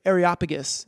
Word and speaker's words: Areopagus: 16, 17, where Paul's at the Areopagus.